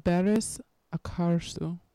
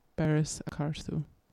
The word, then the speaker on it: Barış Akarsu: Next up, we're going to listen to Bir Sevmek Bin Dafa Olmek Demits, long song, by the artist Barış Akarsu.